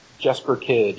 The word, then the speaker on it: Jesper Kyd: I want to know what it was like the first time you heard uh, some of Jesper Kyd's, and I'm not even sure if I'm pronouncing his name correctly. I think he's Danish. Jesper Kyd, yeah. It is Jesper Kyd.